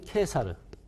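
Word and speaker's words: Caesar: 이분의 이름은 하나의 발음은 케사르. 그 다음에 또 다른 발음은 카에사르입니다. 카에 사르. 자, 카에사르라고 발음하면 고전 발음, 케사르라고 발음하면 로마 발음 또는 교회 발음이라고 합니다.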